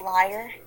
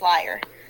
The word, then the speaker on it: lyre: Is is it pronounced liar? – Yeah. – Yeah he Apollo gave Orpheus his first lyre, which is I'm imagining a human just like being handed to Orpheus, just like that lies all the time.